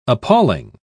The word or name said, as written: appalling